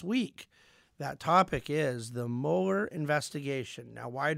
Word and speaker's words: Mueller: and no one has ever explained to me why it's pronounced Mueller when it is spelled Mueller. But it is, in fact, pronounced Mueller, and it is, in fact, spelled Mueller.